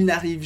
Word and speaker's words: n'arrivent: De novo, com a conjugação de il, eles, nt, que a gente não pronuncia, n'arrive, ils n'arrivent jamais.